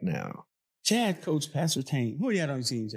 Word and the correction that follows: Pat Surtain: Pat Surtain.